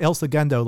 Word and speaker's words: El Segundo: Is it El? How do you say this album? You, it's not El Segundo like the city. – You said it's somewhere. El, yeah, El Segundo, like like the second.